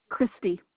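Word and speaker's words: Kriste: Last name is Bergman, B-E-R-G-M-A-N. Zip code 83703. – And may I have your first name, please? – Christy. – Is that spelled with a K? – K. K-R-I-S-T-E.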